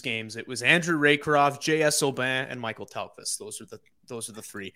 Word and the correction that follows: Mikael Tellqvist: Mikael Tellqvist. I've been pronouncing his name like a dumb Canadian this entire time.